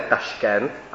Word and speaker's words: Taškent: My mu říkáme tak jako Rusové Taškent, ale uzbecky se to město jmenuje Toškent. Ta výslovnost Taškent je z ruštiny a my jsme ji teda do češtiny přejali.